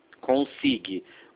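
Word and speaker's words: eConsig: Consig. O G é mudo.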